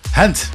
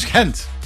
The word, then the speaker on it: Gent: Ik zeg, Hent. Wat wil je nou, man? Hent. Hent. Net als Gent.